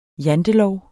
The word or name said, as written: jantelov